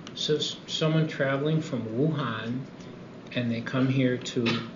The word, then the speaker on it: Wuhan: and so anyone traveling to Wuhan is mandatory, am I saying that right, Wuhan, China, mm-hmm.